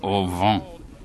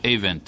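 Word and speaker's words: Avent: Et il disait quelque chose comme « au vent ». Et quand j'ai téléphoné à cet individu pour faire sa connaissance, j'ai dit « comment est-ce que vous prononcez, comment vous prononcez votre nom ?» Et il a dit « non, c'est Event ».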